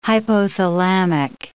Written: hypothalamic

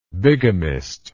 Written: bigamistically

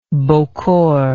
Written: bocor

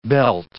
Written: belt

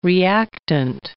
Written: reactant

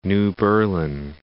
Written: New Berlin

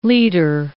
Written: litre

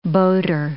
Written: boater

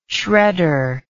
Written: shredder

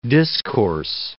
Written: discourse

